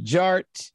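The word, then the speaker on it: DJ Artt: And my name is Jared, aka DJ Art, with two T's for a double dose of that tink tink. The D is silent, so it's just Jart.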